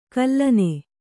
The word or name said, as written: ಗುಲ್ಲನೆ